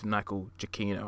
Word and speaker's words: Giacchino: As for the film score by Michael Giacchino, actually no, it's Michael Giacchino. I need to say his name properly.